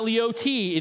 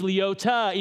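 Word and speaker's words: Leoti: It's not Leote, it's Leota, even though it's spelled Leote, but that makes perfect sense to me.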